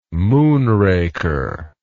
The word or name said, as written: moonraker